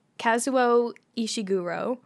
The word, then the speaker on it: Kazuo Ishiguro: Never Let Me Go by Kazuo Ishiguro.